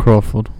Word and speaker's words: Crawford: Och jag har idag en målvakt, Corey Crawford. Uttalar man hans namn så? – Correct. Crawford.